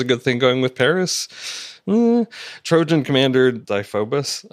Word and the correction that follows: Deiphobus: I forgot how it's pronounced now. You know, I'm So, yeah, who cares? – okay. Trojan commander Deiphobus comes in and vaguely accuses Xena of being a spy.